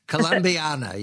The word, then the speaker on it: Columbiana: I imagine it happens in Beaver County and in Columbia. Columbiana County? I'm sorry, I mispronounced that. Columbiana, yes.